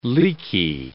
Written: leaky

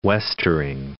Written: westering

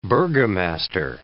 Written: burgomaster

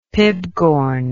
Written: pibgorn

